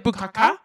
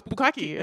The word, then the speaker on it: bukkake: We were like, bukkaka? And he's like, no, it's bukkake.